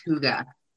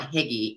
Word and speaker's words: hygge: It's a Danish term spelled Hygge, and it's pronounced hygge, but I call it higgy, even though I know that's not how you pronounce it. But